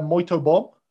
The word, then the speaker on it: muito bom: It's all looking, how you'd say, muito bom right now for your country. – I was wondering how you're going to pronounce that. Really good.